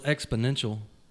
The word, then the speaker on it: exponential: Was giving me a ration of shit on Facebook about X-Potential. – Yeah. – What was that about? Did I say it a lot? Did I say it wrong? – Well, it's exponential. – X-Potential. – No. No, exponential.